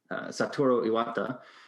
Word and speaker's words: Satoru Iwata: So then the president, Satoru Iwata, mentioned that. – Well done. – What's up? – I said good pronunciation. Well done. – Yeah, that's a tough one.